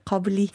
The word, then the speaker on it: Kabuli: Zweitens steht hier afghanisch kochen und essen, zum Beispiel Kabuli, wie spricht man es aus? – Ja, Kabuli.